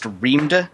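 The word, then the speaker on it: Reamde: Well, the book I read was called, I guess it's pronounced Reamda. It's like a readme file, R-E-A-D-M-E, but the D and the M have been swapped, and that's a bit of an obscure plot point.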